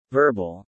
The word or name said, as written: verbal